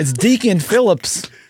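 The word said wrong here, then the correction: Phillippe: Yeah, let me pronounce it correctly for you. Deacon Philippe.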